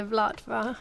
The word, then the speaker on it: Vltava: It's well, the Czechs say the Vltava. There's a famous piece of music by one of the best-known Czech composers, who's Miss Smetana. The music's actually called the Vltava.